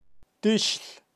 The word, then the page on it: tixl: Kaqchikel